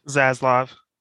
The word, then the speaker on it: Zaslav: Zaslav.